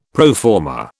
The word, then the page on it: pro forma: British English